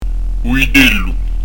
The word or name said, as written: u vitellu